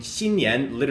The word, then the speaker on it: 新年: So 新 in the first tone is X-I-N in the first tone, means new. And then 年, which is N-I-A-N in the second tone, 年, that means year. So saying 新年 literally means new year, which is how you say Chinese New Year's in Chinese.